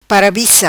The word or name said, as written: Parabica